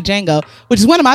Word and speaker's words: Django: Django. – Nah, nigga, it's a D in that bitch. – He said in the movie, the D is silent.